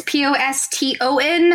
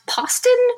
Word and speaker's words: Poston: It's Poston. I know it looks like it should be pronounced Poston, but it's not.